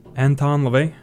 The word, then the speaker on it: Anton LaVey: Anton LaVey? – Yeah.